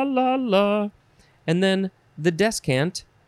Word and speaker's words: descant: Yeah, descant. D-E-S-C-A-N-T. First form. You could also spell it and pronounce it. Discant.